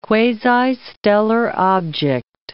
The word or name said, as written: quasi-stellar object